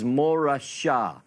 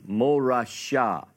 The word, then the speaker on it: morasha: In Hebrew, the word inheritance is morashah. You could spell it in English, M-O-R-A-S-H-A, morashah.